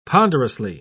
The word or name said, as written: ponderously